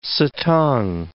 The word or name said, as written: satang